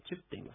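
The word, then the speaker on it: チㇷ゚: チップ、アイヌ語の船のことを、チップと言います。まあ、ちょっとこれね、チにちっちゃいプがつきます。これアイヌ語独特の発音ですが、まあ、とりあえずこのチップという言葉の発音練習をしてみようと思います。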